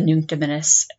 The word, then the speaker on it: Nunc Dimittis: So that was the baby we named Simeon because we were thinking about the Nunc Dimittis, and I don't think I said that right, but. – Yeah, you did.